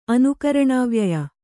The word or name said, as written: ಅನುಕರಣಾವ್ಯಯ